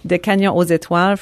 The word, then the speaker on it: Des Canyons aux Étoiles: Olivier Messiaen was a very famous French composer, wrote a piece that is called, and I'm going to give you again the French pronunciation. – I love hearing it. Please do. – Des Canyons. Des Canyons aux Étoiles, From the Canyons to the Stars, which is a work he wrote, I believe, after visiting the canyons in Utah.